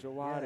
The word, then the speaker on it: Djawadi: By the way, I'm not going to be able to pronounce his name right, but the composer for Pacific Rim was Ramin Djawadi. – Thank you. – Ramin Djawadi. – Djawadi. – Yes.